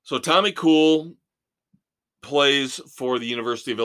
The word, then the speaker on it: Kuhl: Tommy Kuhl, K-U-H-L. I anticipate, I suspect that name is pronounced, though, Kuhl.